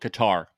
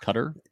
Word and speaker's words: Qatar: Some call it Qatar, but I believe that it's pronounced Qatar.